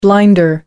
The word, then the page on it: blinder: American English